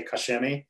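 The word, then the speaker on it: Koch-Chemie: So technically the proper way is Koch and then Chemie, but they miss the K in the middle. So it's just, I always say Kosh Chemie because that's basically how they say it. Cause they just kind of skip that K sound in the middle.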